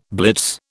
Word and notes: blitz: British English

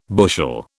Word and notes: bushel: British English

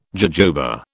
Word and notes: jojoba: British English

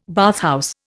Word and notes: bathhouse: Indian

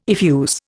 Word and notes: effuse: Indian

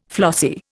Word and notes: flossy: Indian

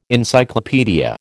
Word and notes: Encyclopaedia: American English

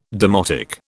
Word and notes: demotic: British English

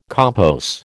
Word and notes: compos: American English

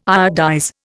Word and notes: iodize: Indian

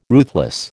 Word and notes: ruthless: American English

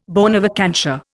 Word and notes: bona vacantia: Indian

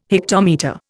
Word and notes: hectometre: Indian

